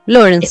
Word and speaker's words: Lawrence: Por último tenemos a Bradley Whitford, que es el comandante Lawrence, creo que se pronuncia este. – Sí, Lawrence.